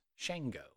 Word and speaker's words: Shango: So S-H-A-N-G-O, but it's pronounced Shango.